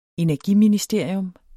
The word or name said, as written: ENERGIMINISTERIUM